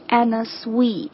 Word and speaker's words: Anna Sui: 的时候，好像有看到说他这个这位设计师 Anna Sui，他的本姓实际上是姓肖，但是好像是说在填报这个户口还是干嘛的时候被搞错了，所以就写成了这样现在的这样的一个拼写，他并不是姓苏的，他实际上是姓肖的。 Anyway，正确的发音应该是 Anna Sui。